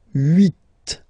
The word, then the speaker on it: huit: Then here, final T is pronounced. So huit, huit. Remember in French, this H letter here. Okay, doesn't exist phonetically, so we don't really pronounce it.